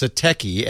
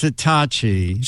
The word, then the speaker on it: Satechi: It's Satechi, S-A-T. – I asked the PR lady. She said, no, it's Satachi. – She said it's Satachi, but it's spelled... – Yes. I know. It's S-A-T-E-C-H-I.